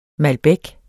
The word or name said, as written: MALBEC